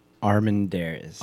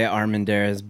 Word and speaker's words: Armendariz: I say Armendariz, but if you ask, like, some people will come up to me and or they'll read my last name properly, and it's Armendariz.